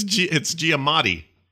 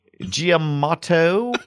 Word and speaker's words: Giamatti: It's Giamatti. – Giamatto.